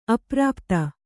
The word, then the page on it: ಅಪ್ರಾಪ್ತ: aprāpta; Kannada